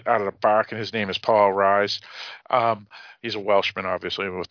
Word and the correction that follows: Rhys: Actually, R-H-Y-S. – R-H-Y-S, yep. – Yeah, it's yep. Reese. – Reese, okay, that's how you pronounce it.